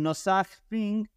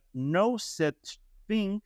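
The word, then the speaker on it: Nosaj Thing: Tenemos a otros que se llaman Nosag Thing, que en realidad se debe de pronunciar No Such Thing.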